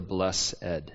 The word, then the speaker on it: blessed: Or who are the blessed? Or if you prefer the churchy pronunciation, who are the blessed? Right? It's funny when you lead prayers, some say blessed, blessed. It's kind of a potato-potato thing.